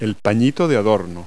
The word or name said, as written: doily